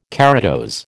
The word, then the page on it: keratose: American English